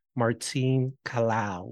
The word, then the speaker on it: Martine Kalaw: And today we have author, speaker, um, DI consultants, spokesperson on immigration, and all around superwoman, Martine Kalaw.